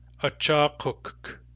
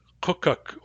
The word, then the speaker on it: Achakuk: There are three syllables in this word. The syllables are a, cha, kuk. That third syllable is the tough one. Kuk. Kuk. If your first language is English, you will really want to stick a vowel between those two K's. You'll want to say kukuk or kukka because that makes it easier to say. However, there is no vowel in the Nuchanoth pronunciation of that word.